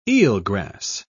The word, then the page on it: eelgrass: British English